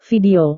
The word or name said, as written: video